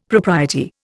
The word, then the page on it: propriety: Indian